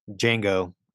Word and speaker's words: Django: The D is silent. Payback won't be. – Django.